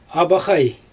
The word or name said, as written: abaxaj